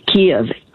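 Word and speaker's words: Kyiv: then take off songs in the key of Kiev. Kiev is how English you should pronounce the capital of Ukraine. It's not Kiev, it's not Kiev like the Russians.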